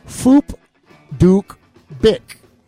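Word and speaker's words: Phuc Dat Bich: His name is pronounced, the best I can do, Foop Duke Bick. And so when it's written down, though, that's how it's pronounced. But when in print, it is P-H-U-C, then D-A-T. – D-A-T. All right. – B-I-C-H.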